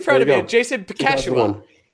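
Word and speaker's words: Buchnevich: Bakash Hihua. – You got close. Bakashua.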